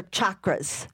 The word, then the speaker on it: chakras: Is it chakras or chakras? – The proper way to pronounce it is chakras, like the hard CH, like church.